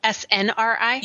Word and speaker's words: SNRI: I decided that I wanted to instead switch to an SSNI. Am I saying that right? – SNRI? – Yes, and SNRI.